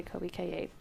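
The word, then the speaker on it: Colbie Caillat: And I don't want to butcher her name, but it's Colby Kaye. – Kaye. – Yeah. Okay, Colby Kaye.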